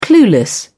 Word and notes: clueless: British English

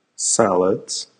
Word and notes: salads: American English

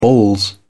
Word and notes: balls: British English

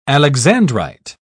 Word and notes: alexandrite: British English

